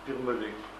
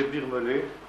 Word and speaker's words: Birmelet: Comment ? Birmelet. C'est comme ça se prononce. André Birmelet. Birmelet.